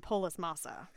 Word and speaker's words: Polis Massa: Many people saying she was born on Polis Massa.